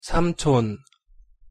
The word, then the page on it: 삼촌: Korean